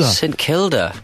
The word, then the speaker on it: St Kilda: St Kilda. – Anyway, what do you know about it? Tell me what you know about it. – Rhymes with Hilda. – It does rhyme with Hilda, yeah.